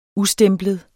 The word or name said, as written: USTEMPLET